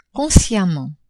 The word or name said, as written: consciemment